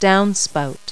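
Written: downspout

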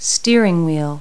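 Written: steering wheel

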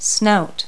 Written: snout

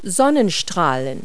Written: sunrays

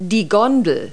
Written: gondola

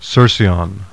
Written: CERCYON

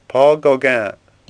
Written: Gauguin

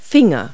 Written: fingers

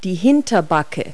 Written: haunch

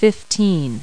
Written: fifteen

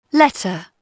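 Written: letter

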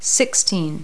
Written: sixteen

